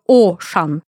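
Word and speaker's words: Auchan: Но после этого, они, ну, подумав, Жерар поменял название на уже привычный нам «Ашан» с вот буковкой «А» в начале, которая, кстати, читает, этот звук, этот дифтонг читается во французском как «О». Поэтому правильнее было бы транскрибировать его как «О-шан».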